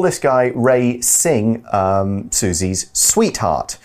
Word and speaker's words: Singh: Next, we see that the article says Ruth knows that something is wrong and forms a close friendship with Susie's school sweetheart, Ray Sai. – Uh, Singh. – Singh. No, yes, yeah, pronounced Singh.